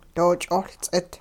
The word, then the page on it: doo-ch'ohLts'it: hupa